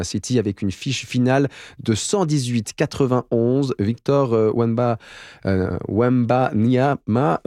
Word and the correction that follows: Wembanyama: désolé, Wembanyama, voilà, ça se prononce comme ça, a récolté 28 points pour les Spurs.